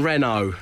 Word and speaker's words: Renault: My in-laws pronounce the T in Renault.